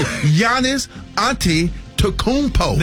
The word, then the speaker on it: Giannis Antetokounmpo: Well, the Milwaukee Bucks, they are back in the strong consideration of trading Giannis Antetokounmpo. Did I say it right? – Let me see. – Giannis Antetokounmpo. – There you go. – Come on now. – Yeah, you got it right, baby.